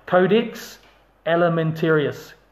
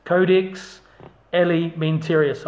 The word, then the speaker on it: Codex Alimentarius: Codex Alimentarius. Codex Alimentarius. I'll spell that for you. C-O-D-E-X. Codex, as it reads. And then Alimentarius. Alimentarius. Codex Alimentarius.